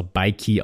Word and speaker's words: Bike: Und wie gesagt, ich habe es auch extra Bikey ausgesprochen, weil, wie gesagt, wegen Nike, aber ich finde es super cool, diesen Hint.